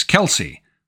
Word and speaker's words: Kelce: Our first film is Kelsey. That's spelled K-E-L-C-E.